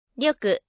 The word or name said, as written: 力